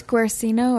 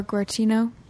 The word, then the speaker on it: Guercino: It's Guercino or Guercino.